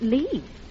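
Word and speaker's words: Lits: I know it's spelt Litz, but over here they pronounce it Lee.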